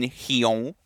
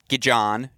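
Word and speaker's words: Gijón: I am staying so far away from tomorrow's match in Hyon. Hopefully you all appreciate my pronunciation there. Hyon, Spain, not Gijon, not Gijon. It's Hyon.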